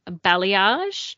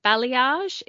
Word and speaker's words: balayage: In case you were wondering out there, the correct pronunciation of this word is balayage. – Yeah. – It is a bit confusing when you see it, but balayage is what you want to ask for.